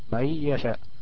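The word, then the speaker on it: من يشاء: النون نون ساكنة، وجاء بعدها ياء متحركة، وهي من حروف الإدغام بغنة، الإدغام بغنة. فهتتنطق ازاي؟ النون الساكنة ديت هتقلب ياء وتضغم في الياء التي تليها، يعني كأنها ميم ياء ثم كلمة يشاء، كويس؟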